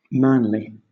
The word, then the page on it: manly: British English